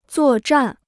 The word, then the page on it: 作战: zuò zhàn